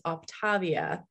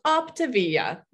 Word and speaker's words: Optavia: I always thought it was Optavia, like kind of Shakespearean. – Sounds so pretty. – Doesn't it? Nope. Optavia. I'm like, great. So we've got two dominant syllables in one word.